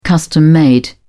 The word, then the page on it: custom-made: British English